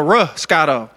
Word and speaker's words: Roscato: No, Roscato. – Roscato? – Yeah, I think it's R-O. Wow. Ain't that what it is? It's a Moscato? – It's like Moscato, but it rhymes with it.